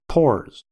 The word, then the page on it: pores: American English